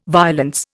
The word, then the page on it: violence: Indian